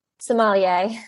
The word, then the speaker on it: sommelier: how the hell do I say sommelier? How do you say that? – Sommelier. – Sommelier. Thank you very much.